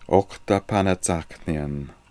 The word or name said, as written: Okhtapanatsakhnian